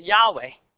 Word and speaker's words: Yahweh: We pronounce it Yahweh, but it's only got four letters. It's all consonants. We put the vowels in there and we say Yahweh.